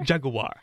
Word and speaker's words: Jaguar: Jaguar is how they say it in the commercial.